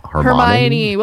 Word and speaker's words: Hermione: Is it like Hermione? – Hermione.